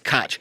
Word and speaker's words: Koch: Where I grew up, if it's spelled like that, it's Koch.